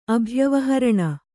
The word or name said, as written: ಅಭ್ಯವಹರಣ